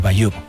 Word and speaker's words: Bayeux: El tapiz de Bayeux.